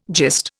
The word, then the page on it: gist: Indian